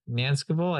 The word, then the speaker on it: Nancekivell: All right, I'm here with Steven Nancekivell.